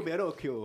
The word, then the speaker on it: Bellocchio: Marco Bellocchio. – Marco Bellocchio.